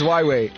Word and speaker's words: Huawei: Till this day, I still can't pronounce Huawei. I don't know if it's Huawei or Huawei. – Your second attempt was right. – Huawei. No, I can't pronounce that word. – It's Huawei.